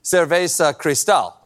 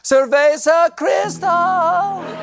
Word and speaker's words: Cerveza Cristal: Now, it was subtle, but you may have noticed a tasteful product placement for the Chilean beer Cerveza Cristal. I'm sorry, I mispronounced that. Cerveza Cristal!